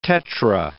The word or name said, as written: tetra